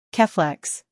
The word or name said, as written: Keflex